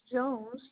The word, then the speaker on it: Jones: First name is Delisa, D-E-L-I-S-A, last name is Jones, J-O-N-E-S.